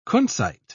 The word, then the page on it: kunzite: British English